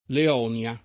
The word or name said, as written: Leonia